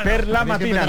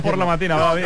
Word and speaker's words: per la mattina: Per la mattina, no por la mattina.